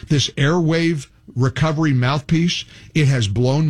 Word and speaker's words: Airwaav: Airwave is spelled, it's Airwave, but it's spelled A-I-R-W-A-A-V. So remember that W-A-A-V spelling.